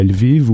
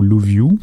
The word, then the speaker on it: Lviv: Le nouveau carrefour migratoire principal dans le territoire ukrainien, c'est plus Kharkiv, mais c'est Lviv ou Louviou. Il y a différentes façons de le prononcer en français.